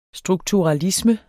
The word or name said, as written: strukturalisme